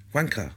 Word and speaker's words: Juankar: Okay, and my son speaks Spanish fluently, so I said, how do I pronounce his name? And he said, well, it's pronounced... Wanker.